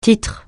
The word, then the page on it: titre: francais